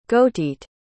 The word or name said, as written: gauteite